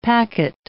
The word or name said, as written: packet